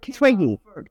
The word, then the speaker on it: Twiggy: I remember that, I vaguely remember the Tugi. Is that how they pronounce Tugi? When the miniskirt Twiggy. – Twiggy.